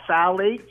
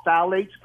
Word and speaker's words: phthalates: So essentially, when those plastics melt into the food, you're eating what they call phthalates. It starts with a P, but the P is silent. It's called phthalates.